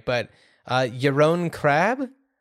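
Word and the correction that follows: Krabbé: Yaron Krabbe. – Yaron Krabbe, who's a Dutch actor who I also I really put him.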